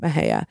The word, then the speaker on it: Mexia: Mejia is spelled M-E-X-I-A, so it could be said Mexia, but everything I read about it, everyone was calling it Mejia. Actually, one of their slogans is, it's a great town, no matter how you pronounce it. So I'm pretty sure there's not a wrong way, but I'm going to call it Mejia.